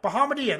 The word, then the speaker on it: Bahamadia: Bahamadia, Bahamad. Bahamad. How do you pronounce it again? Bahamadia? Bahamadia. Bahamadia, that's right, Bahamadia.